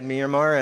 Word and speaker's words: Myanmar: So they had to leave Myanmar, that's how you say it, Myanmar, and then they're in Thailand there in Chiang Mai and seeing what the Lord will have them do.